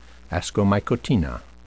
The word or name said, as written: ascomycotina